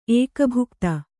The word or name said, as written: ಏಕಭುಕ್ತ